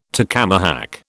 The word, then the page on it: tacamahac: British English